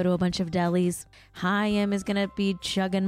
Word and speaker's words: Haim: I have wronged you all because I mispronounced Haim. Haim. Okay, the band that I formerly called Haim is actually pronounced Haim. Haim is the band's name, not Haim.